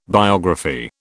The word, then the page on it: biography: British English